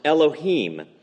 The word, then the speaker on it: Elohim: In Hebrew, the word here that Asaph uses in Hebrew is pronounced Elohim.